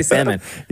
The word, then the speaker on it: salmon: Do you pronounce the L in salmon? – Hell no. – No, I just say salmon.